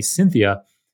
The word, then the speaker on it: Syntheia: So it sounds like the woman's name, Cynthia, but it's spelt like synthetic. S-Y-N-T-H-E-I-A, Cynthia.